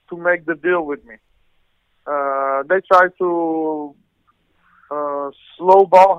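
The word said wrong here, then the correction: lowball: how you say it? – Low ball.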